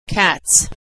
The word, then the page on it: cats: s